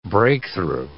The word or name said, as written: breakthrough